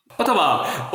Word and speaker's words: Ottawa: Ottawa.